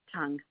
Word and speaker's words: Tung: Tung, T-U-N-G. – T-U-N-G.